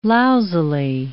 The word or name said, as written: lousily